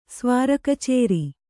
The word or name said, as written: ಸ್ವಾರಕಚೇರಿ